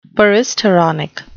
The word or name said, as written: peristeronic